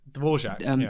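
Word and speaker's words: Dvořák: Dvorak, yeah.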